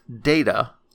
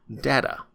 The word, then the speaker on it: Data: I default to the android himself when he corrected somebody that his name is pronounced Data, not Data. And when asked what the difference was, he said, one is my name, the other is not.